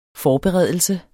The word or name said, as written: FORBEREDELSERNES